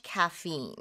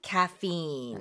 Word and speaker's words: caffeine: Your blood pressure can become elevated if you have too much caffeine. – 카페인을 너무 많이 섭취하면은 혈압이 높아질 수 있습니다. 네. 그리고 카페인을 영어식으로는 어떻게 발음하죠? – Caffeine. – That's right. Caffeine in English, but in Korean, caffeine.